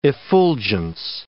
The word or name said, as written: effulgence